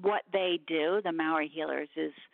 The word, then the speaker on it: Maori: Maori, yeah, you're saying it right.